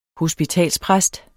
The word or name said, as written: hospitalspræst